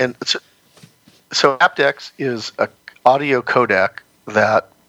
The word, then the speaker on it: aptX: And the other thing is that aptX is starting to finally, you know, and for our listeners that don't know what aptX is, it's spelled APTX.